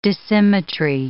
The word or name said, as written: dissymmetry